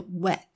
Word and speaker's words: wet: wet 湿这个字呢它是短母音那 weight 它呢是长母音，那个 a 的音。 alright，所以一个是 wet，一个是 weight。好，这个发音要注意咯。